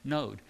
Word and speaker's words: Nod: And then if you look at the word nod, it's just moving that vowel point up to the top of the letter, and that tells you that it's supposed to be like a long O node.